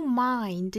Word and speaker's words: mind: If your mind is open, 我们注意这个 mind 的发音呢是双元音 I 的口型要到位，然后后面呢再加一个前鼻音 Mind 我注意到有同学会把它读成 mind，这样一定是一个错音，也会引起歧义的，所以注意是 mind。